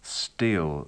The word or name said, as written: steal